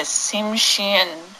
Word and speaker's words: Tsimshian: Kushtaka are mythological shape-shifting creatures found in the stories of the Tlingit and the Tsimshian Indians of southeastern Alaska's temperate rainforest. – Notice I actually pronounced the names correctly the first time.